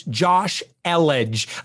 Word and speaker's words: Josh Elledge: I want to make sure you pronounce my name right. It's Josh Elledge.